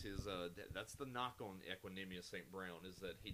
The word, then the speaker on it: Equanimeous: No, that is exactly how you pronounce that name. It's Equanimeous St. Brown. Let's all enunciate and say it together, ladies and gentlemen. Equanimeous St. Brown.